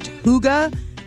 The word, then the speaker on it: hygge: Went to a poga party. – A hooga party. – A pooga? What is that? – I've never been to one before. It's weird because it's pronounced hooga, but it's spelled H-Y-G-G-E.